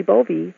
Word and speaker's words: Bowe: My last name, Bovee, is spelled B-O-W-E, but the W is pronounced like a V, which is the German pronunciation.